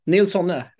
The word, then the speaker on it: Nilsonne: Nils Sonne, absolut.